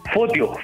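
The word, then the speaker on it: Photio: Fotio, Fotio con F. Ah, ya no me quise equivocar porque se escribe con PH.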